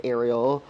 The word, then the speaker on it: Ariel: and my name is Ariel. – Did I say that right? – Yes, okay, you did. Cool. Yeah, okay. Yeah. It's like the mermaid.